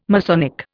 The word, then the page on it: Masonic: Indian